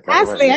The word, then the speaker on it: Passley: Yeah, Passley.